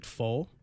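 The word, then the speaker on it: Pfolh: And I think it's pronounced Full, but it's P-F-O-L-H. – I had a friend in college whose last name was this, and he pronounced it Full.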